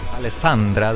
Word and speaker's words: Alessandra: Alessandra, that's how you pronounce it.